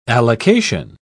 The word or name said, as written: allocation